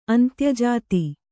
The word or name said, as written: ಅಂತ್ಯಜಾತ